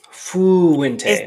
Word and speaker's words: Fuente: And not just isn't it Fuente? – It is. Fuente. – Don't you pronounce he pronounces it weird. Like I think it really is Fuente.